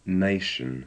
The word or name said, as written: nation